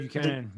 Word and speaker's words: Buchanan: Pony Buchanan Loop? Is that how you say it? – Buchanan. Yep.